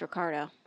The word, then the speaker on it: Ricardo: It's Ricardo. Little Ricky's his alter ego. – Gotcha. – You gotta, are we doing it right now? – You got to roll your R's.